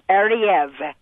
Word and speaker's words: Ariew: A-R-I-E-W. – Yes, pronounced Ariev.